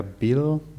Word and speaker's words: был: Вчера я был дома. – Да, вчера я был дома. Dieses, die Aussprache von dem Ü muss man ein bisschen üben. Das ist so, man verschiebt die Zunge ein bisschen nach hinten.